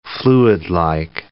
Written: fluidlike

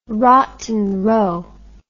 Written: Rotten Row

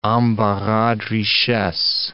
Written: embarras de richesse

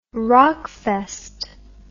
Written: rockfest

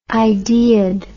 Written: ideaed

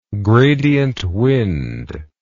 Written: gradient wind

